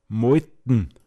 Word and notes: griaseg: Pinzgauer Mundart

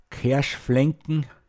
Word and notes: Keckadschaufe: Pinzgauer Mundart

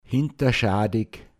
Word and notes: angsteg: Pinzgauer Mundart

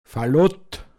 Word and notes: fårfe(l)n: Pinzgauer Mundart

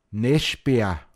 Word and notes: nei(d)ln: Pinzgauer Mundart

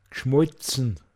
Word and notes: glåod ziimascht: Pinzgauer Mundart

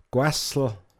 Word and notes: auf da Påz fåon: Pinzgauer Mundart

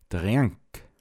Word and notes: påtschad: Pinzgauer Mundart

